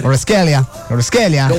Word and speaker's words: Kvaratskhelia: Ahí donde ve a Don Tirson, cuando él se ve la Champions, él pronuncia bien Kvaratskhelia. Él no duda, él lo dice bien, derecho, bonito. Sí. – Como tiene que ser. – Kvaratskhelia.